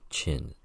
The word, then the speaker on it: Qin: He was Qin, pronounced Chin, C-H-I-N, whose successors built the Great Wall and gave a name to China.